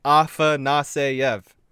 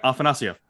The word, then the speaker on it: Afanasyev: I'm not trying to rag on people being like, get it right, but it's like Afanasyev. – It's one of those things that it's almost spelled phonetically. – Yeah, just, and if you say it fast, it kind of works. – Yeah, Afanasyev. – Afanasyev. – I mean, and you listen to Russian broadcast, that's Afanasyev.